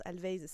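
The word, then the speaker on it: Alvvays: Du coup, je suis allée faire quelques recherches sur ce groupe Always, mais qui s'écrit avec deux V. Du coup, ça se prononce Always, ça me déstabilise.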